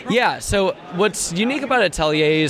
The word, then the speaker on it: atelier: You're one of the developers, or the developer of, how do you say it, Atelier? – It's Atelier. It's a French word for a like an artist studio.